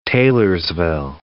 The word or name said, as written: Taylorsville